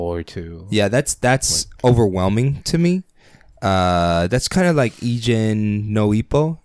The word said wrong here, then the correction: Hajime no Ippo: Am I saying that right? – Hajime no Ippo.